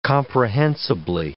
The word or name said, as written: comprehensibly